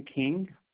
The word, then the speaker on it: King: Yeah, last name King, K-I-N-G.